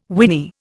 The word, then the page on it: winy: Indian